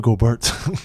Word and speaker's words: Gobert: And Gorbert. Hey, look at that. Look at that. You got the two like biggest motherfuckers in the paint. Rebounding wise, say his name again. – Who? Rudy Gobert.